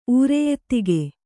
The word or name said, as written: ಊರೆಯೆತ್ತಿಗೆ